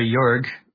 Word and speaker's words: Jörg: The I know that the time spent here in hiding from the authorities is when he assumed the name Junker Jörg, which is spelled like Junker George. If you were to spell it out in German, and if you pronounce it in English.